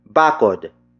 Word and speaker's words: bakod: Bakuran is spelled as B-A-K-U-R-A-N. It means backyard. Bakuran comes from the root word bakod, which is spelled as B-A-K-O-D.